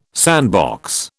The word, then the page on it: sandbox: British English